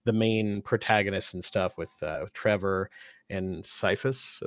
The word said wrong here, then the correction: Sifa: Am I saying that right? – Sifa. – Okay. Yeah. I always add a letter for some reason.